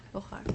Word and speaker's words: Gaukhar: G-A-U-K. H-A-R. Gohar.